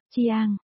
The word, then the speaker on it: Giang: Khương trong Khương Vô và Giang trong Giang Sam là đồng âm. Đều đọc là, Chiang,